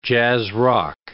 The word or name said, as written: jazz-rock